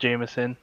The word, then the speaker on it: JMSN: The artist we're listening to is JMSN, or Jameson, pronounced Jameson. He's an R&B kind of like alternative R&B kind of soul act.